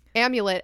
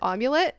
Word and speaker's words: amulet: But this what this girl is referring to in her letter is that Natalia accidentally pronounced the word amulet as omulet.